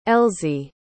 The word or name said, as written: elzy